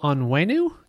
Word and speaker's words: Onwenu: Patriots putting left guard Michael Onwenu.